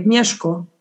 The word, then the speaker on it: Mieszko: Also ich meine, ich habe mich natürlich jetzt auch für diese Sendung mal ein bisschen kundig gemacht, ob das jetzt Mieszko, wird der Mieszko ausgesprochen, 966, der quasi sich nach römisch-katholischem Ritus taufen lassen hat. – Ist auf jeden Fall M-I-E, Mieszko.